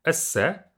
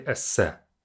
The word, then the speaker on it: эссе: And I think, don't say it, even though it sounds like an English word, don't say it like the English word essay, because the A at the end is not existent. It's just simply эссе. Эссе.